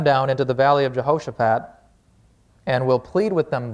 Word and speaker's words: Jehoshaphat: Just for some fun word study here, Jehoshaphat, that's how you say it, Jehovah Shaphat means God has judged.